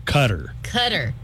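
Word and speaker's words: Qatar: Extramarital sex is a crime in the country of Cotter. But how do you say it is? – Cutter.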